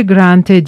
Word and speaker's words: Grantaire: Dipnot, Grantaire gibi Grand R, büyük R de Grante diye okunur.